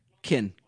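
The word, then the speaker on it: Qin: Is it Quinn or Ken or Jin? – Kin. – Kin.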